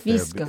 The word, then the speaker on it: fisco: Fisco is how you pronounce it. Fisco. There, which means taxes.